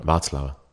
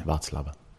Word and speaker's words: Václav: Václav. – Ah, okay. – Václav.